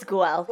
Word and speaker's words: Guel: It's Guel.